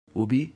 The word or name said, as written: ubi